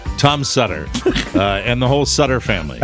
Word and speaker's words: Sutter: We'd like to say hi to all our listeners in Columbus, Ohio, Sutter Creek, California, Sutter. If you don't know, rhymes with butter.